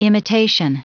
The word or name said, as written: imitation